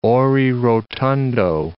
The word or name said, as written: ore rotundo